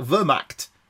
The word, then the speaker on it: Wehrmacht: Wehrmacht.